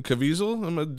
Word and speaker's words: Caviezel: It is a 2000 movie featuring Dennis Quaid, of course, and Jim Caviezel.